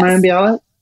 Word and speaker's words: Mayim Bialik: It's that Mayim Bialik, is that how you say her name? – Yes. – Mayim Bialik? – Yes.